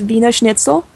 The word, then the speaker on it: Wiener Schnitzel: That's a good question, because people would probably tell you that it's Wiener Schnitzel, which I pronounce English now. It's Wiener Schnitzel in German.